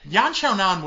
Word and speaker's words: Yan Xiaonan: Yan Xiaonan would have made way more sense.